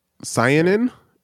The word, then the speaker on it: anthocyanin: Anthocyanin